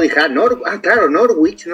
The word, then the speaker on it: Norwich: No, él, no decía exactamente Norwich.